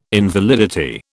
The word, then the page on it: invalidity: British English